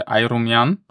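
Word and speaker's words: Ayrumyan: No, vyslovuje se to, že Ajrumjan,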